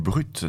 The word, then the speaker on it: BRVT: Il y a eu Brut d'abord avec le morceau Vague. J'aime vraiment beaucoup leur EP à Brut. Je vous rappelle que ça s'écrit BRVT.